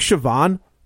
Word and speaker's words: Siobhan: It was like Siobhan. Like, there's a character in Superman comics called the Silver Banshee, and her name, her real name is Siobhan, but it's spelled like S-O-B-I-A-N. So I was like, Sobian.